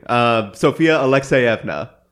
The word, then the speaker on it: Alekseyevna: Sofia Alekseyevna.